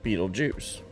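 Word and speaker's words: Betelgeuse: Now, you will see it in the movie spelt differently. It's spelt differently, but it is pronounced Beetlejuice.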